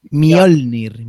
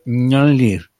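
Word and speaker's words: Mjölnir: Mjolnir, Mjolnir. Mjolnir. Pues yo digo, como si fuera mi Olnir y ya está, y no te complica. Vale, es que es MJ, Olnir. Claro, yo siempre le he dicho Mjolnir.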